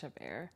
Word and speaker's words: Chabert: Yeah, I think it's Chabert.